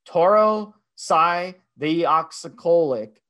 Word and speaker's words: tauroursodeoxycholic: Taurocydeoxycholic.